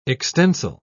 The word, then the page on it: extensile: British English